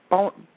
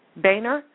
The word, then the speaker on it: Boehner: Okay, while the White House and the House Speaker John, how do you pronounce his name? Boehner. Boehner. – I always call him Boehner, but yeah, it's like Boehner. That's what I wanted to call him, but I knew it wasn't right.